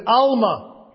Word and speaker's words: almah: There the word virgin, it's a Hebrew word, which is Alma. You would spell it in English, A-L-M-A-H.